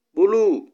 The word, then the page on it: buluu: Kiswahili